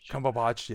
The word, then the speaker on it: Cumberbatch: Cumberbatch, yeah.